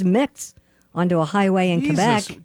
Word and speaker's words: Quebec: Quebec. – Quebec. That's how they say it in Canada.